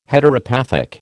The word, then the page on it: Heteropathic: American English